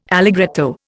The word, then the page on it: allegretto: Indian